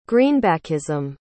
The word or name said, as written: greenbackism